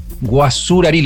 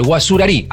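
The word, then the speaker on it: Guacurarí: Ahora, cuando sabíamos que íbamos a charlar con vos, ahí leí un poquito sobre quién fue Andrés Guasarurí, lo sigo diciendo mal, Guasarurí. Ahí está, ¿no? Ahí lo dije bien.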